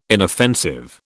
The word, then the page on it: inoffensive: British English